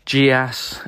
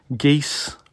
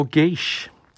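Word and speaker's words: geas: And then, of course, I'm so bloody glad I didn't call in, because only last night in our game, I discovered that the word which I've been pronouncing as GIAS, occasionally GIAS, the old TSR guides, there are some guides which do say GIAS in exactly that way, with the soft G and the ass at the end. It is actually a quite direct Gaelic borrowing, which is more correctly pronounced geese or geesh.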